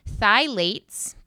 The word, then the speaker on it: phthalates: Phthalates, but there's a P in front of that, but it's pronounced phthalates.